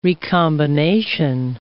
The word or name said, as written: recombination